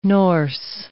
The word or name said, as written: Norse